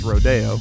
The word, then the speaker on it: Rodeo: Travis Scott featuring Casey Hill from his album Rodeo, or if you're above Venice Boulevard, it's pronounced Rodeo.